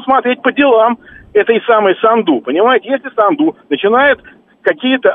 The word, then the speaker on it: Санду: Ну, то есть вы хотите сказать, что, смотрите, мы должны, если Санду или Санду, я так и не понял, потому что слушатель сказал, что на последний слог ударение правильно, а сам называет ее на первый.